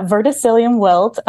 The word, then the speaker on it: verticillium wilt: I hope I'm pronouncing this correctly, but what is verticillium wilt, which is the villain in your story? – Yeah, verticillium wilt.